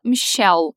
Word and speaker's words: Michelle: Se escreve Michelle, igual em português, mas se fala Michelle. Igual aquela música dos Beatles, sabe?